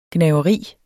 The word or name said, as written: gnaveri